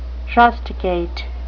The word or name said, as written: rusticate